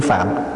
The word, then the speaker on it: Phạm: mà mình bây giờ hay gọi là tiếng Phạn đó, chứ đúng ra phải đọc là tiếng Phạm.